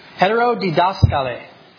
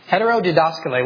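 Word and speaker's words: heterodidaskaleo: This word here for otherwise or for different doctrine, I'll pronounce it and see if you can pick it up. Heterodidaskale. Heterodidaskale, where we get heterodox.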